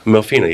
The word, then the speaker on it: Melfina: Oh, Melfina? – Melfina, yeah.